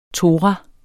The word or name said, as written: TORAER